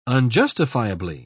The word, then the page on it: unjustifiably: British English